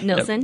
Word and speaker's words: Nilsson: Am I saying it right? Nilsson. – Yep, Jake Nilsson.